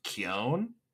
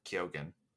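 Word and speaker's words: Keoghan: One, it's pronounced Keown, Barry Keown. – Oh, okay. Not Ke- not Keogan, Keown.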